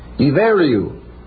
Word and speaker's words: Iveriu: One of the ancient names of Ireland is I-B-H-E-R-I-U. Pronounced I-B-E-R-I-U. Iveriu. But that's the way it's spelled. Probably the word was derived from a still earlier pronunciation, Iberiu.